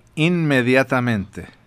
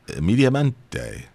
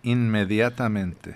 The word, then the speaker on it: inmediatamente: How do you say immediately in Spanish? – Inmediatamente. – That wasn't even close what I said. – That's not even close to what you said. – Immediamente. – Inmediatamente.